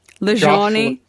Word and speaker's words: Lajani: There's so many great people to follow, like Tim Kaufman, Josh, how to pronounce his surname? – Lajani. – Josh Lajani.